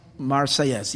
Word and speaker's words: Marseillaise: The French national anthem, and I don't know how you pronounce it, the what is it? La Marseillaise? What? Marseillaise. Yeah, that's what I read, but I didn't know how to pronounce it.